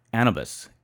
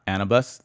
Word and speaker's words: Anubis: The pronunciation of Anubis. It's not Anubis. The correct pronunciation is Anubis.